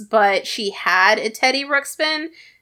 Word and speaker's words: Ruxpin: But our next one, a Teddy Ruxpin. It's Ruxpin.